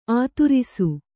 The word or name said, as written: ಆದರ್ಶ